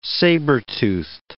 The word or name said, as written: saber-toothed